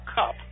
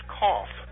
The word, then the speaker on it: Kopf: They spell that K-O-P-F, just like in Fall City, what we call cup. But out here it's pronounced cough. K-O-P-H, cough.